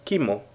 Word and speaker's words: Chemo: Yo me he quedado con un personaje que me llamó muchísimo la atención, que no conocía, y era, no sé, se supone que se pronuncia Kimo, ¿de acuerdo? Pero se escribe Chemo. Yo, por supuesto, le llamo Chemo.